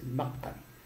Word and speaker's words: المقري: وهو التقى، هذا لأن هو بقي 15 سنة في فاس، عاش وهذا المقري، نطقه هكذا، مش بيهم ينطقوا، المقري، المقري، الميم فتحة،